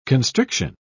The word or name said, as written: constriction